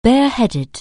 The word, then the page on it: bareheaded: anglais